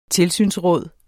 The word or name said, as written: tilsynsråd